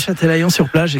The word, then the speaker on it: Châtelaillon-sur-Plage: Ouais, Châtelaillon-sur-Plage, exactement, à côté de La Rochelle.